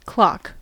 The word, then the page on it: clock: American English